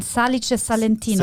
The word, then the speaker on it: Salice Salentino: Nein, Salice Salentino. Salice? Nicht Salice. Salice. Salice.